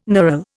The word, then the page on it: knurl: Indian